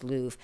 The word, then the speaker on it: Louv: Richard Love, or Louv, I believe his name is pronounced Louv, L O U V.